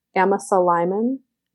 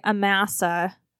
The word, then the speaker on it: Amasa: Wait, how did you pronounce it? – Amasa Lyman. – Okay. – I pronounced it the Mormon way. – I thought you were talking about a woman in Mormon studies I didn't know. I always say Amasa. But no. I think that there's some debate in the historical community about the pronunciation of his name.